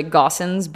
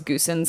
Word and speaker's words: Gossens: His name's Quentin, but, and his last name's, it's actually Gossens, but for a long time we thought it was Goosens because that's how it's spelled.